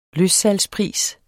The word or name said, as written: løssalgspris